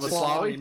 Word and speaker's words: Maslany: Maslany. Something about Maslany.